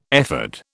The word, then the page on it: ephod: British English